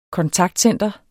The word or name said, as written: kontaktcenter